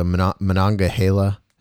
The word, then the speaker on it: Monongahela: the Monongahela.